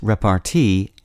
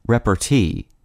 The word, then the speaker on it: repartee: The word of the day for April 25th is repartee, also pronounced repartee and repartee. The word is spelled R-E-P-A-R-T-E-E.